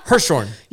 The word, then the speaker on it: Hirschhorn: Well, it's Elizabeth, and how do you say her last name? – I say Hirschhorn. – Hirschhorn. – Yeah, I think... – It's H-I-R, so Hirschhorn. – Yeah, Hirschhorn.